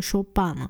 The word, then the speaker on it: Chopin: 还有一点很有趣的是哦，我们现在讲肖邦哦，他的名字英文发音我们就叫 Chopin 哦，其实 Chopin 是法文哦，可是如果说是英文的话应该 Chopin 对不对，可是好像没有人会讲 Chopin 呢，就是 Chopin 听起来就是比较有气质嘛。